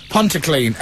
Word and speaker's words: Pontyclun: Just sounds like the word pont, and then the word clean. – That's right. – Okay, it really is that simple. Pontyclun.